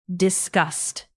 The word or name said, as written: disgust